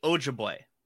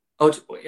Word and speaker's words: Ojibwe: C, Ojibwe. – Ojibwe, okay, yeah. – You are correct.